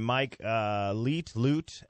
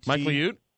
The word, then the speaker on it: Mike Liut: Mike Liut, L-I-U-T. – Mike Liut? – Hold on.